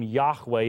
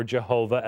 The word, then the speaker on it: Yahweh: To you it was shown that you might know that the Lord, and when you see L-O-R-D in all capitals in the English translation, that's the name Yahweh in the original language, or Jehovah as it's not as well pronounced, that the Lord, He is God, there is no other besides Him.